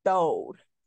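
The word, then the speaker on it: throwed: But yes, it's spelled throw, but you say Thode.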